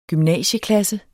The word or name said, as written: gymnasieklasse